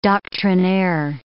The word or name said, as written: doctrinaire